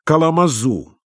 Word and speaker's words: Каламазу: Если в Америке человек из города Каламазу, ударение на зу, в штате Нью-Йорк вдруг заговорит по-английски, его засмеют до уничтожения, говоря по-нашему.